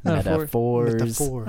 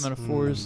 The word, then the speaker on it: metaphors: And all these different metaphors in throughout the movie, because that's how you pronounce that word. Metaphors. – Metaphors. – That's where the accent goes.